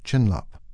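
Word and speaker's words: chinlap (བྱིན་རླབས): The Tibetan word for blessing is pronounced chinlap and means transforming into magnificent potential.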